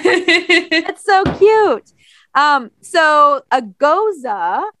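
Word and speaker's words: Gose: And I pronounce it ghost, but here on the beer it says that you should pronounce it goza. It actually shows you goza. – Goza. – Goza. Uh-huh. G-O-E-S-U-H, goes, pronounced goza.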